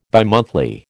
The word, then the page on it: bimonthly: American English